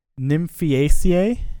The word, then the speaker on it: Nymphaeaceae: So this one is pronounced Nymphaeaceae.